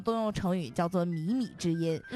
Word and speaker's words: 靡靡之音: 嗯、下一个成语呢叫做靡靡之音，听过吧？ – 靡靡之音听过。 – 错误的读音应该叫靡靡之音，靡靡之音。